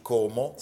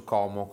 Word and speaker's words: Como: che io la pronuncio Como con la O aperta. – Io no. Sono mica lombardo. Como.